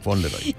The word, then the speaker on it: Fauntleroy: El seu nom complet és Donald Fauntleroy. Ui, no ho va pronunciar molt bé. – Fauntleroy, sí, sí, ho has dit. – Fauntleroy.